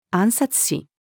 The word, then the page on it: 按察使: female